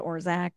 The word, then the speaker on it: Orzech: You can follow me on Instagram at Tessa Orzech.